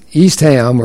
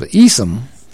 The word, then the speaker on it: Eastham: If you pronounce the street and the prison as Eastham or Eastham instead of Easom, it shows you do not know your Huntsville history,